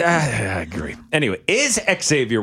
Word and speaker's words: Xavier: He kept calling him Xavier. And somebody in the comments said, no, his name is Xavier. It was a video you put out in college, like asked him, how is your name pronounced? And he's like, oh, it's not Xavier. It's Xavier.